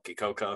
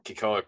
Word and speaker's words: Kekoa: He played at Cal, Kikoka. I'm probably saying that wrong. Kekoa Crawford.